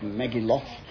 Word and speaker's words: Megillot: The scrolls together are called the Megillot, which is spelt Megilloth. But it's Megillot, and those books are Ruth, Esther, Lamentations, Song of Songs, and Song of Solomon, and Ecclesiastes.